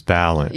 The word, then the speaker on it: Balint: Balint, more of the emphasis. Balint. – Yeah. – Okay. All right. I wanted to make sure I got that correct because I've heard it pronounced different ways.